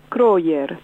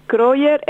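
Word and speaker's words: Krøyer: Pero, por ejemplo, el más conocido es Kroyer. – ¿Cómo se escribe eso? – Con una K, Kro. – Sí, Kro. – Y-E-R, que te lo pronuncio de la manera más castellana posible.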